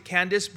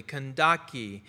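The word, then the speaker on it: Kandake: Now, Candace, I know we pronounce it Candace, but as I looked it up, it was, I believe it's pronounced differently, Kandaki.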